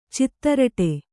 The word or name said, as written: ಚಿತ್ತರಟೆ